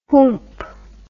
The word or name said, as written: whoomp